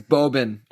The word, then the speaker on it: Bobin: It is Bobin. And no one has ever corrected me at Bobin. So in my head, I always say Bobin.